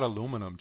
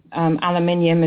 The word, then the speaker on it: aluminium: What about aluminum? Do you use aluminum over there, or do they have those aluminum baking pans? – No. No, I stay absolutely away from aluminium, as we pronounce it.